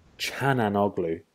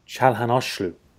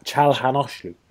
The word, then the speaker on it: Çalhanoğlu: Also, I realized recently I've been saying this wrong, but I thought it was Hakan Çalhanoğlu, but it's Çalhanoğlu. Çalhanoğlu. There you go. See? Çalhanoğlu.